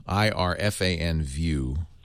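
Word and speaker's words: IrfanView: IRFAN. I said it wrong. IRFAN view.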